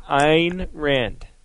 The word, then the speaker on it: Ayn Rand: Because she's going to be incredibly impressed with how I pronounce this next person's name. This is a quote from Ayn Rand. – Good. Very good, Jay.